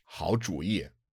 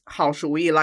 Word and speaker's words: 主意: 好主意. Did I say that right? – No, the second character is second tone. – Really? Because usually it's a third tone, right?